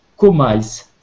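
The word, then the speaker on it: KOMYS: Ce code, c'est COMICE. Ça s'écrit K-O-M-Y-S. K-O-M-Y-S. Ça se prononce Comice Et ça, vous allez projeter ce code dans la bulle et vous allez le dire sept fois.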